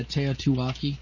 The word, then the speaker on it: Teotihuacan: Some of you guys know about the Teotihuacan, if I pronounce that right. I've always heard it's, yeah, Teotihuacan.